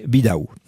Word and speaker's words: Bidaou: Alors, c'est-à-dire la forme locale du prénom, en fait, c'est un prénom, hein, Bidaou, c'est Vidal, qui est, vous savez, le V se prononce B à l'origine, ici, et puis le L, à la finale, se vocalise en E.